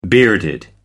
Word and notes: bearded: American English

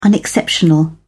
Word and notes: unexceptional: British English

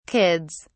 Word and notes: kids: British English

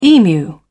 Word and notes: emu: British English

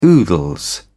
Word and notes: oodles: British English

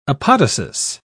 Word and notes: apodosis: British English

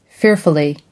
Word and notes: fearfully: American English

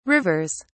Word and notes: rivers: British English

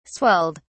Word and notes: swelled: British English

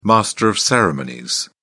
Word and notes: master of ceremonies: British English

